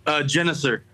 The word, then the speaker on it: Genuser: Genuser.